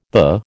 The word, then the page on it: the: American English